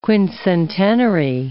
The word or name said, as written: quincentenary